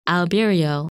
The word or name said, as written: Albireo